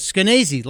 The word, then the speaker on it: Skenazy: The lady's name is Lenore Skenazy, and I know that's how it's pronounced because when you ask her, she says Skenazy, like crazy.